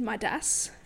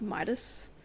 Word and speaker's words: Midas: And the way, it's probably because the narrator was British, but she said Midas, not like Midas. That's like, it so sounds weird. Like one syllable instead of like the two. – Yeah. – Midas. Midas.